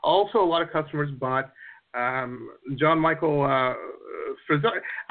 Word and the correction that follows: Ferrari: I'm probably saying his name wrong, aren't I? Ferrari, right? – Ferrari. – Like the car. – Ferrari, just like the car.